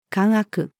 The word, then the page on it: 姦悪: female